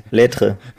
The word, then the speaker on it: Lettre: Lettre. Also L-E-T-T-R-E.